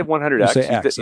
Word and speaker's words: 100X: Or do you pronounce it internally in your head 100 times leader? – No, I say 100X.